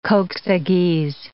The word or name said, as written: kok-sagyz